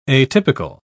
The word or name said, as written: atypical